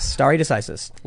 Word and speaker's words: stare decisis: Stare decisis. – Stare decisis.